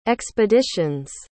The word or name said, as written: expedition's